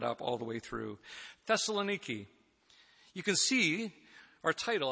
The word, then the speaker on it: Thessaloniki: Today, we're beginning a new sermon series that focuses on Paul's first letter to the church in Thessaloniki. I got it on good word from the Emanatides that that's the pronunciation that we need to go with.